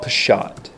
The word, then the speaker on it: Peshat: The first is called Peshat. You can spell it P apostrophe S H A T, Peshat.